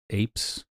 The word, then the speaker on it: apse: Is it apes? Apes? How do you pronounce that? – A-P-S-E.